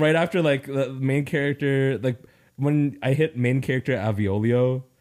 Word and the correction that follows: Avolio: Avolio. – Damn it. I knew I said it wrong again.